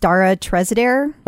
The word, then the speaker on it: Treseder: So this is all about, I believe it's pronounced Dara Trezeder.